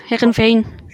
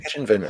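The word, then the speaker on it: Heerenveen: 2004 to 2006, Herenveen. I don't know how to pronounce that in Dutch. Can you help me out? – Yeah, that was pretty good. Herenveen. – Herenveen.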